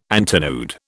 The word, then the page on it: antinode: British English